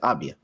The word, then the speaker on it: Avdija: Yeah, that's how you say it. Avdija.